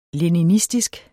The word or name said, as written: leninistisk